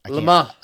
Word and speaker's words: Le Mans: Le Mans, I don't know how to pronounce it. – Le Mans.